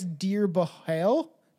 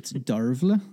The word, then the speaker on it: Dearbhail: It's literally spelled like Dearbhail. So I guess Dearbhail. Dearbhail. – It's Darvla. – What? – It's the Irish spelling for it. – What Darla? What'd you say? – Darvla. – Darvla. That's a name? – Yeah. – Darvla. Not Darla.